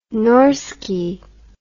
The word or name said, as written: norski